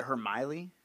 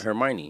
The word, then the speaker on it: Hermione: Isn't it Hermiley? – No. – What? – Hermione. – Okay, I'm not from here, Hermione? But you definitely haven't watched that Hermione.